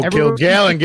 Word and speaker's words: Kilgallon: If they think it – to me, it's just one of those things that don't get so fucking sensitive if Joe Killian's going to go and make a couple jokes on fucking stage. Fucking laugh about it. – Joe Kilgallen, get his name right. Show some respect.